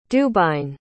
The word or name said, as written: dubhain